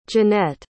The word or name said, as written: jeanette